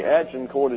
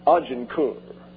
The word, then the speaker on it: Agincourt: And by the way, Agincourt is the English pronunciation.